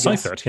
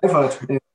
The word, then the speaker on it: Seifert: I don't know how to say his name in English. Alwin Seifert, I guess. – Seifert, yeah. Let's get Seifert in German.